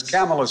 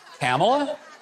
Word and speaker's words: Kamala: As Kamala said, Kamala? – I thought it was Kamala.